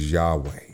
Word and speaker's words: Yahweh: YHWH is just the way it's spelled, it's Hebrew, and that's the way that it's written. It's Yahweh.